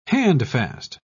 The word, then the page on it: handfast: British English